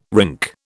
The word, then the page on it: rink: British English